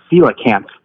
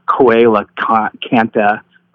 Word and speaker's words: coelacanth: Aaron, by the way, it's coelacanth, not coelacantha, or however you said it, when talking about that prehistoric fish that they found.